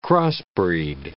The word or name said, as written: crossbreed